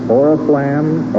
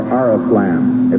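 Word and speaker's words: oriflamme: This may be pronounced either Oriflam or Ariflam.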